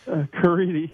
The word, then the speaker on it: Caridi: But to be honest, did you and I ever think this Tony, how do you pronounce his name, guy? – Caridi.